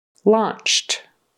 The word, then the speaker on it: launched: And it's a ah, ah for that C-O-M. It's not com, it's ah, competition. Launched. Launched. That E-D on the end becomes a T because the C-H is unvoiced, so the sound at the end becomes unvoiced, which is a T instead of a D.